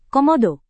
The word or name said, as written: komodo